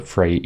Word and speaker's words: Freire: Paulo Freire is his name, or Freire, to get it pronounced, I think, closer to correct.